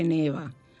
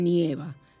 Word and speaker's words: nieva: Lo que pasa es que no lo notamos como las personas en otros países donde nieva, que así es que se dice, no se dice neva se dice nieva, nevó y nevará.